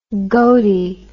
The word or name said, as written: goaty